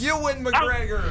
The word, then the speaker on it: Ewan McGregor: If I'm saying his name right, Edwin McGregor? – You were so close. It's Ewan McGregor. – Oh. Yeah. You combined it with Edwin McCain, I think.